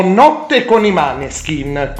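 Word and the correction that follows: Måneskin: Co- anzi, pronuncia giusta, scusami, pronuncia giusta i moneskin.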